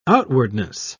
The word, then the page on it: outwardness: British English